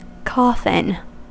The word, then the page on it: coffin: American English